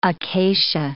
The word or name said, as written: acacia